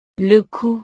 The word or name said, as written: le cou